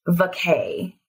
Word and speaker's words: vaccae: I think it's pronounced Vacae, V-A-C-C-A-E.